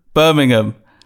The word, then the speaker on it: Birmingham: Just how do you pronounce it? – Birmingham. – Birmingham? – Yeah, no, I live in Birmingham. – I'm going to say they're spelt the same.